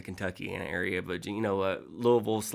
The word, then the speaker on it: Louisville: And you're from, I'm going to hopefully say this right, Louisville. – That's pretty close, Louisville. Yeah. – Louisville.